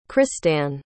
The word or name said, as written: christanne